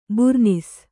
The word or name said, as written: ಬೂರ್ನೀಸು